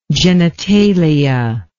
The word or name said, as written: genitalial